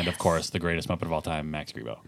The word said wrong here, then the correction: Max Rebo: Max Rebo. – Max Rebo, not Grebo.